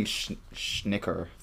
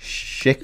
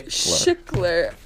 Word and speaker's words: Schickler: This week we read The Smoker, published in The New Yorker by David, last name I can't pronounce, Schickler. I'm really bad at pronouncing names. – I thought it was, I was saying Schnicker the whole time, like Snicker. Schickler. – Schickler.